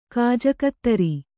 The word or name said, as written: ಕಾಜಕತ್ತರಿ